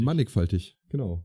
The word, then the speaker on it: mannigfaltig: Mannigfaltig, genau. Mannigfaltig. – Mannigfaltig, also ein G, ein Sch.